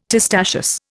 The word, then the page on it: testaceous: Indian